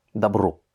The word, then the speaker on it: добро: Le troisième mot, Добро, lui encore, il a l'accent tonique sur le dernier O, et donc le premier, qui n'est pas accentué, va se prononcer comme un A. Добро.